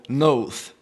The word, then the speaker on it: Noth: So to repeat, Cain was the only witness for the defense and was spared but ostracized into the wilderness called Noth. It's Nod in your English Bibles. But the character that you see there that looks like a seven, that gets a TH sound, so it's Noth, a long O.